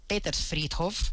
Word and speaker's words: Petersfriedhof: sin visitar el coquetísimo Petershof, eh, perdón, Petersfriedhof, vamos a pronunciarlo bien,